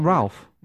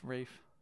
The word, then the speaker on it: Ralph: Is it not Ralph? – Nope, it's Rafe. I know. – It's Ralph. – It's Rafe. – Ralph. – Look it up on Wikipedia, and there's a pronunciation. Rafe.